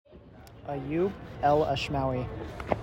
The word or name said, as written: Ayoub El Ashmawi